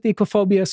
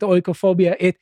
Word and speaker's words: oikophobia: I pronounce it ecophobia. Some people pronounce it oikophobia. You won't get Greeks... modern Greeks, at least, to pronounce it this way, but this is disliking oiks, you know, the people you see.